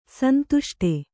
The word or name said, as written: ಸಂದಷ್ಟ